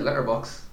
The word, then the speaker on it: Letterboxd: Do you say the appearance to D? Do you? – It'd be weird if it was a silent D, I'm not gonna lie. – I always just said Letterboxd. – Okay. – I don't know, Letterboxd. – you don't overpronounce it.